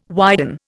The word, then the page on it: widen: Indian